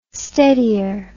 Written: steadier

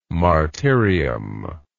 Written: martyrium

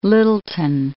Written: Lyttelton

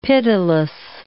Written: pitiless

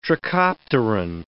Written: trichopteran

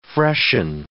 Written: freshen

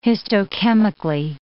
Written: histochemically

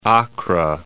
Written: Accra

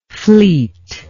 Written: fleetness

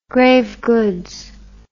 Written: grave goods

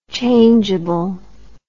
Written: changable

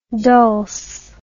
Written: dulce

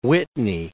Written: Whitney, Mount

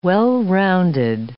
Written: well-rounded

